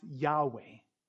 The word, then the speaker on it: Yahweh: pronounced Yahweh.